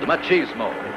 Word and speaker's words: Machismo: It's called Machoism. Machoismo. 40 Graves for 40 Guns. I think I'm saying that right. – Harry Novak presents the first motion picture bold enough to be called Machismo.